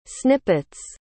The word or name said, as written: snippets